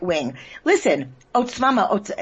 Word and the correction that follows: Otzma Yehudit: how do you say it? The Jewish party. – Otzma Yehudit.